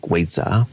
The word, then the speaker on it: vidya: And the Burmese pronunciation of vidya is something like weza.